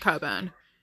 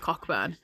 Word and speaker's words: Cockburn: I know it's pronounced Coburn, but it is Cockburn.